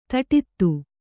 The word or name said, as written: ಧಡೂತಿ